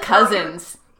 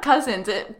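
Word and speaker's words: Kuzins: Alyssa Cousins. – Your name is beautiful. – Cousins. It's spelled differently than cousin. It's spelled all wonky. It's K-U-Z-I-N-S.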